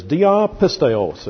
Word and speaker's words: dia pisteos: The Greek is dia pisteos. If you want to transliterate that, it would be D-I-A-P-I-S-T-E-O, long O-S. Dia pisteos.